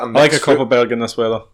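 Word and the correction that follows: Kopparberg: You said it wrong the first day, I think. – Copperberg. – Yeah, you said Cockerberg first.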